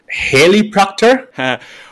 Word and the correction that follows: Heilpraktiker: Well, no, it's Heilpraktiker, which literally translated means heal practitioner.